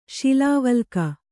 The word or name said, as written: ಶಿಲಾವಲ್ಕ